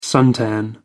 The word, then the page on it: suntan: American English